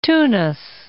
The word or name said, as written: Tunis